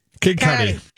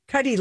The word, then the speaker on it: Cudi: Kid Cudi. – Kid Cudi. Cudi, like Cudi Sark.